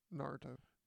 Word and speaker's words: Naruto: And you guys watch Naruto. Did I say it right? – Naruto.